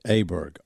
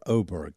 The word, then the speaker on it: Åberg: Ludvig Åberg or Åberg, you pronounce it the way you wish. It's an A with a little O over the top of the A.